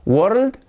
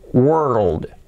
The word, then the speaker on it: world: Porque tenemos la palabra de nuevo, word, pero tenemos que meter una L en medio. ¿Y cómo nos queda? ¿Nos queda world? ¿A beautiful world? No. ¿Cómo se pronuncia esto? Agarraos que vienen curvas, es world, world.